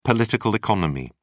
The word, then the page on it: political economy: British English